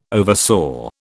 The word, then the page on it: oversaw: British English